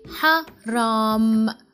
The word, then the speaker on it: حرام: And there is ha at the beginning, okay? حرام, حرام. And remember, Arab people, they pronounce ra like this way. That strong ra. Actually, strong any letter.